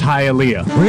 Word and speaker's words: Hialeah: You're Hialeah PD. – It's pronounced Hialeah.